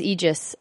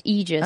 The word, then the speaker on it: Aegis: correct is Aegis. It is a word of Greek origin and is pronounced Aegis.